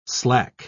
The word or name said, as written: slack